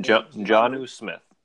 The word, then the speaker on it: Jonnu: Jonnu Smith.